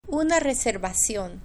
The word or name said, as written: una receta